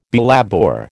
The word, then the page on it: belabour: American English